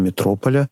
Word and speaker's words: Метрополя: Действительно, Владимир Высоцкий для «Метрополя», именно так принято ставить ударение, подобрал несколько текстов песен, которые представил в виде стихов.